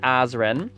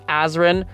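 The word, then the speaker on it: Azren: It is at Polyglot Azran. That is spelled P-O-L-Y-G-L-O-T. A-Z or Z, depending on your country, R-E-N. You can also simply search Azran the Language Nerd on your favorite social media platform, and you'll find me that way.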